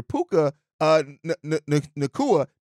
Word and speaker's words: Puka Nacua: Puka last year, and I said Nuka earlier, Puka Nacua. That's how you say it.